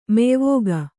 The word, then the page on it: ಮೆಯ್ವೋಗ: Kannada